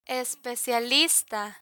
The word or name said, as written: especialista